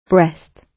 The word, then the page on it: breast: British English